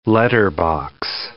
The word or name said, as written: letterbox